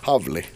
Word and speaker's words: Hawley: Howley. Jag tror att det är Howley. Havli, så ska det uttalas.